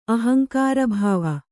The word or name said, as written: ಅಹಂಕಾರಭಾವ